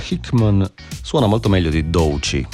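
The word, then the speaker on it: Doechii: Doici.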